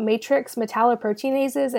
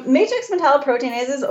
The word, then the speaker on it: matrix metalloproteinases: but can you talk about matrix metalloproteinases and what those are and how do they factor into oral health? – For sure. Yes. Yeah, you said it right. – Awesome. – matrix metalloproteinases,